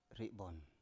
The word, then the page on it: ri7bon: Biak